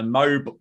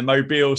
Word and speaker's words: Mobile: the the name of the town is different from if you're saying mobile phone, aren't you? – Interesting, an interesting quirk of pronunciation there.